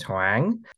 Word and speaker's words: Hoang: My birth given name is Hoang, which, if I'm correct, is spelled H-O-A-N-G.